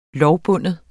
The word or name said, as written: lovbunden